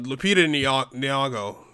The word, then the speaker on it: Lupita Nyong'o: But yeah, Lupita Nyong'o, I'm butchering her name right now. – That's exactly how you say it.